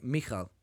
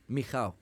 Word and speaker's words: Michal: I want to make sure I'm saying your name right, Michal. – Almost. Michal. – Michal. Oh, that's better. Okay, Michal.